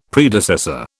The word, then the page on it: Predecessor: British English